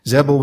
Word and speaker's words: Zebul: Zebul was there. That's how you say his name.